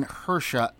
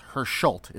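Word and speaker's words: Hersholt: She's getting the Jean Hersha Humanitarian Award. Herscholt is the correct way to pronounce that.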